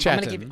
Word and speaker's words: chaton: C-H-A-T-O-N. – Yeah! – C-H-A-T-O-N. – But that's pronounced Chatton.